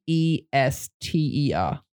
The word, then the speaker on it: Leicester: So this is spelt LeicesterR.